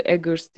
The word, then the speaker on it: Eggers: Robert umarım soyadı Eggers diye okunuyordur. – Doğru okuyorsundur.